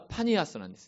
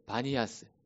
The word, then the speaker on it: バニアス: パンという神を拝む神殿が一つあるんですが、昔はパニアスなんですね。でも、このシリア人、アラブ人たちは、ピという発音ができない。それで、ピをビに変えました。それで、バニアスというようになっています。